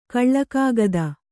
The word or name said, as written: ಕಳ್ಳಕಾಗದ